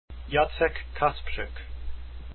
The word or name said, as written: Kasprzyk